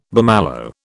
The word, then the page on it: bummalo: British English